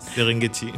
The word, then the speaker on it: Serengeti: On parle donc du tourisme, de la forêt impénétrable de Bwindi en Ouganda, des parcs de Serengeti, hein, j'espère que je prononce bien. – Serengeti. – Voilà,